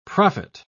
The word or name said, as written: prophet